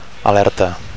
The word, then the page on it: alerta: Catalan